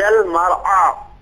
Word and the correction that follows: المرعى: ابھی بھی عین میں ایک تفخیم کا ہلکا سا شائبہ ہے اس کو بھی انشاءاللہ تمرین پریکٹس کریے تاکہ بہتر ہو جائے.